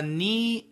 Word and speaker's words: knee: A knee jerk reaction, a knee jerk response. Knee, K N E E. You know, your leg, your foot, your knee. Knee, K N E E. The K is silent.